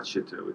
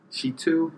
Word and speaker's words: Shittu: Although I know it's not Shittu. It's Shittu. They don't want you to pronounce Shittu. – I spell it. So, yes. S-H-I-T-T-U.